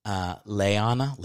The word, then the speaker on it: Liana: But she's like, her name is spelled Liana. And then she's like, it's actually pronounced Leana, like Leana Hammock.